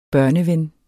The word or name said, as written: børneven